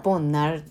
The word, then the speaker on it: 복날: 韓国的には「ボンナル」っていう風にアクセントをするっていう、はい、感じです。